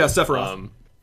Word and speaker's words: Sephiroth: Yeah, Sephiroth